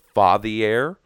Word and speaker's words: Fathier: Those are called, I believe the pronunciation is Fathier. It's like F-A-T-H-I-E-R.